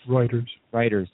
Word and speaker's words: Reuters: Reuters, I'm probably saying that wrong again. – Reuters, thank you.